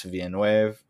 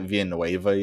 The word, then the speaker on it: Villeneuve: I know one way that it's not pronounced is I heard someone pronounce it Villanueva yesterday. And I was like, that's Denis Villanueva. – I was like, that's not right.